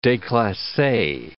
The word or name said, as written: declasse